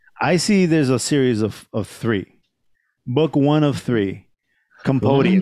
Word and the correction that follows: compendium: Compendium. I'm sorry. Compendium. I'm mispronouncing this shit.